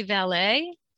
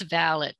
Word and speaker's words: valet: I think in this country we say valet. Is that right? In England, it's valet with a T.